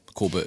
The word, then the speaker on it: Kobe: Also Kobe ist Kobe, sozusagen, wenn man es Englisch ausspricht, ja. Und ja, Kobe ist die deutsche Aussprache.